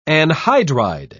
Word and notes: anhydride: British English

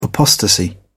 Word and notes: apostasy: British English

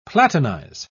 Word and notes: platinize: British English

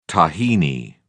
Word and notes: tahini: British English

